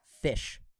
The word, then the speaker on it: ghoti: I forget the actual words, but it's spelled G H O T I. But in English pronunciation, it's pronounced fish.